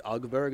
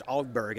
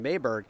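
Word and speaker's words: Åberg: It's all Berg, and everyone's calling him Aberg, but then many times the player himself just says, just call me Aberg, it's fine, and we'll just leave it at that.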